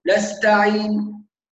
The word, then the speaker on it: نستعين: فمثلا نسمع بعض الناس يقول إياك نعبد وإياك نستعين ويظن أنه كده جاب حركتين، هذه حركة واحدة، نستعين، لازم نستعين، عين، يبقى نضبط الاثنين، طبعا الحركتين يقاسوا على ايه؟